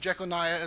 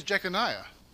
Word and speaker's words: Jeconiah: Okay, so this man, Jeconias, as it's pronounced here in Matthew, is Jeconiah is another way to pronounce it. The Bible spells it different ways, but his name is Jeconiah.